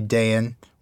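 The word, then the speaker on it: Dáin: And we said that we are, we say Thrayan, we say Dayan, we say Owen and Glowen.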